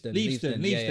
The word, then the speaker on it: Leavesden: They how do you say the name? Is it Leavesden? – Leavesden.